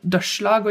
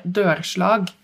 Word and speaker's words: dørslag: ah selvfølgelig og det derfor også riktig uttale jo dørslag og ikke dørslag ja. Som mange sier hvis man bare har sett det skrevet